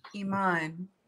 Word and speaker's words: Iman: Iman. – Iman. – One syllable, not Aman, Eman, Emin. No, it's Iman.